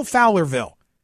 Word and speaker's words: Fowlerville: Uh, how do I say it? Fowlerville. Fowlerville. – That's correct.